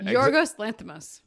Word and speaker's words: Yorgos Lanthimos: And Yorgos Lanthimos.